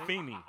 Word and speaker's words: Afeni: How do you say Afeni.